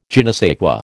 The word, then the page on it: je ne sais quoi: American English